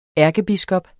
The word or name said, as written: ærkebiskop